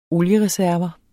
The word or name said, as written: oliereserver